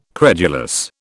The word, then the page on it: credulous: British English